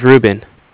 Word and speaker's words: Ruben: My name is Ruben. – R-U-B-E-N? – Yes.